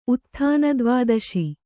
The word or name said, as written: ಉತ್ಥಾನದ್ವಾದಶಿ